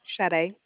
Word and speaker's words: Sade: My name is Sade, just returning the call. – Okay, what was your name? – Sade. – Spell that, please. – S-A-D-E.